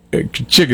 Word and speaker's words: Qing: Qing Dynasty. Qing is right.